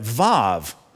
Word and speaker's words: Vav: Even if when I read it, Vav, modern Hebrew grammar spells that letter W A W. It sounds like a V.